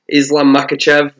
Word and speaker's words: Islam Makhachev: Islam Makhachev versus Rafael Dos Anjos.